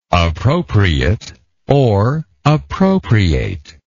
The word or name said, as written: appropriateness